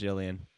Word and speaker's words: Julianne: Isn't her name like Jillian? – It is. Actually, it's spelled Julian. – Oh, it's actually like Julian Fries? – But it's pronounced Jillian. Exactly. It's like Julian Assange.